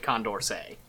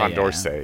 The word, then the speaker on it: Condorcet: So you should pronounce it Condorcet. – Yeah, Condorcet. – Yeah. – That's okay. – The French way to do it if you want to be French about it.